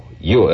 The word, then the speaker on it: Yule: Now, we spell it Y-U-L. Yule, as in Brenner.